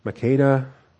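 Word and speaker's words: Makkedah: Makeda. Makeda. Again, select the Hebrew lemma, click on pronounce. Makeda.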